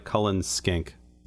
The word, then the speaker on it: Cullen Skink: And you are, I believe, saying it correctly. As it's written, it looks like Cullen Skink.